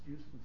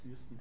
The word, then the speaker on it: Houston: No, it's Houston Street. It's Houston, Texas. There's a problem. These New Yorkers don't know how to speak English. They pronounce it Houston Street.